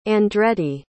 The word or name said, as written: Andretti